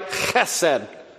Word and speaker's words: chesed: I mean, if you were to say it right, chesed. You have to kind of work on that pronunciation.